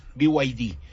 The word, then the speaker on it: BYD: BYD. – BYD.